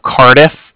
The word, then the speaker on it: Cardiff: I'm calling with the Bank of Cardiff, and that's spelled C-A-R-D-I-F-F, as in Frank, Frank. So it's like car and then different.